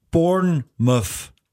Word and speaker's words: Bournemouth: Radio Steiermark läuft und liebe Grüße ins 1420 Kilometer entfernte Bournemouth. – Das hast du schön ausgesprochen.